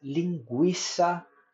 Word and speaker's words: linguiça: Exemplo: aguentar ambiguidade, consequência, delinquente, equino, frequência, linguiça, tranquilo, frequentar. Vocês percebem que em todos os us foram pronunciados. Fracamente, mas foram.